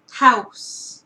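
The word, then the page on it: house: Canadian English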